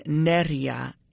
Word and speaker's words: Nerija: The accent is on the E, so it would be Nerija. So I'm gonna play this one with Blue Lab Beats, Moses Boyd, and Nerija, and it's called Pineapple.